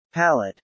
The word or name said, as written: palate